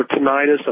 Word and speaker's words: tinnitus: Well, I've been dealing with tinnitus or tinnitus. I'm not sure exactly how it's pronounced. – Tinnitus. That's okay.